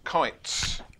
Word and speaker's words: Kuits: Kites Solicitor, I will spell it. K-U-I-T-S, Kites.